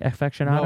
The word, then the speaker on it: aficionado: Did I say that right? Aficionado? – No, it's aficionado. – Aficionado. See, that's close. I said it right.